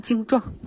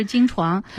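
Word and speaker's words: 经幢: 经床是什么呢？这个床哈、啊，特别容易读错的一个字，它是像这个床是左边是毛巾的巾，右边是儿童的童，经常会读成经状。 – 对对对，实际上它是经床。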